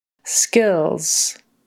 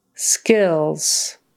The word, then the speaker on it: skills: Skills. Skills. A short I in the middle, eh, and then it's an LZ sound on the end.